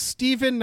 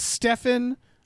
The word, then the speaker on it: Stephen: Thank you to Stephen, quote, it's pronounced Stephen, not Stefan, end quote, Simmons.